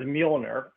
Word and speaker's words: Mjolnir: Yeah, is the J silent or what? Do you? – Kind of. It's almost like, is that Mjolnir? Almost like mew cat. – Yes. – You know, like a cat mew. It's an odd pronunciation. – So we do hear the M.